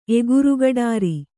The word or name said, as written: ಎಗುರುಗಡಾರಿ